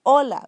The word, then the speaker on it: hola: H, even though I say H, you don't pronounce it. Has no sound. So when you say hola, you don't say hola. You say hola. So please do not pronounce the H.